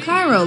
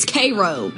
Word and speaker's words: Cairo: Oh, Cairo, like Egypt. No, apparently it's Cairo. I thought this was Salina. No, it's Salina. I worry about this place. – That's funny. – Cairo, Lebanon.